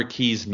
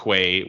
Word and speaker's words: Quay: And we will start in the UEFA Champions League, where Alashkert took on Connor Key's Nomads. It's not Quay, which how I had previously pronounced it in the last episode that we were discussing this. I'm not sure how Q-U-A-Y makes the key pronunciation, but whatever.